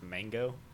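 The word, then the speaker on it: Mang0: I think it's pronounced Mango. – It is pronounced Mango.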